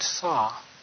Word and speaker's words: Sau: And as you breathe out, you repeat the word saw. It's spelled S-A-U, but it's like a hammer and saw.